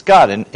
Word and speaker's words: Gad: You know, Gad, God the prophet. It's God in Hebrew, right? You would pronounce it God. It just sounds weird in English, right?